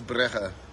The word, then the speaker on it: Brugge: English is Bruges, and then Flemish is Brugge.